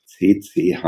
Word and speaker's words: CCH: CCH, Kongresszentrum Hamburg, Betonung auf C, besonders vornehm in Anführungsstrichen.